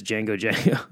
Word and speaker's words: Django Django: They're spelled like, you know, Django Unchained, but just Django Django. You know, like the Quentin Tarantino movie, but D-J-A-N-G-O, and then just twice, Django Django.